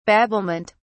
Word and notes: babblement: British English

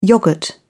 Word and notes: yogurt: British English

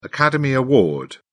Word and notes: academy award: British English

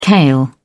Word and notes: kale: British English

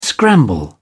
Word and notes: scramble: British English

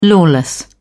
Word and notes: lawless: British English

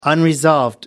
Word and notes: unresolved: American English

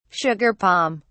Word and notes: sugar palm: British English

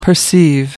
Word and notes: perceive: American English

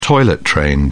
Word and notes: toilet-trained: British English

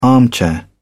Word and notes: armchair: British English

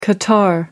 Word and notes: qatar: American English

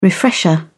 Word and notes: refresher: British English

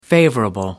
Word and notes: favourable: American English

